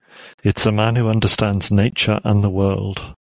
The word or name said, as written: understands